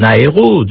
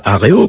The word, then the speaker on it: aéro: Le dialogue entre la famille des mots en aéro, devenu très riche, aéroport, aérospatial, et qu'on a bien raison de demander de prononcer scrupuleusement aéro et non pas aréo, comme ça arrive, celle de nautique, enrichie d'astronaute et astronautique, et enfin celle des mots en avis, oiseau en latin, d'où avion.